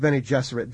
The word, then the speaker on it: Bene Gesserit: What religions mostly go into the B'nai Gesserit? – Well, that I must have mispronounced. – I used Bene Gesserit because I was thinking of female Jesuits.